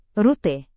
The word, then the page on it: rute: indonesian